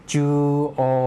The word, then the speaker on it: 中央: ิบันอุชิโรอิจิบันอุชิโรแถวหลังสุดจูโอจูโอตรงกลางครับคำนี้ออกเสียงช้าช้าช้าชัดซะหน่อยนะครับมีตัวโรมันจิจูนะครับ CHO ออกเสียงว่าชุเสียงสั้นและมีอุตามหลังครับอีกตัวหนึ่งกลายเป็นเสียงทำให้กลายเป็นเสียงยาวไปนะครับคนญี่ปุ่นออกเสียงรวบยาวจูแล้วก็ตัวโออุนะครับคนญี่ปุ่นออกเสียงรวบยาวโอครับจูโอ